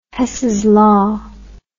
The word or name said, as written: Hess's law